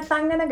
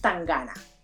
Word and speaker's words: Tangana: No es la Tangana que te dan una piñata, no es la Tangana que te dan... No. Es Tangana. O sea, la pronunciación es Tangana.